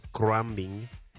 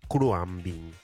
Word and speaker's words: Khruangbin: Estoy hablando de la banda estadounidense Crumbing. Se escribe K-H-R-U-A-N-G-B-I-N. Crumbing.